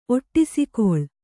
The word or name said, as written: ಒಟ್ಟಿಸಿಕೊಳ್